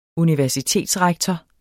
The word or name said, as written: universitetsrektor